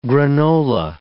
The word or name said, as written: granola